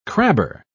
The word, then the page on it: crabber: British English